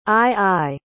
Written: aye-aye

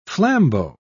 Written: flambeau

